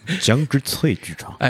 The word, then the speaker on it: 江之翠剧场: 江之翠剧场。